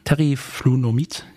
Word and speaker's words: Teriflunomid: Ich muss, da ich MS habe, Teriflunomid, ich hoffe, ich habe es richtig ausgesprochen, mhm. – Teriflunomid, ja.